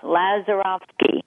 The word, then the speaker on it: Lazarofsky: That's close enough. Lazarofsky.